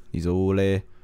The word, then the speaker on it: Isolde: Tristan and Isolde, is that how you say it? – Isolde.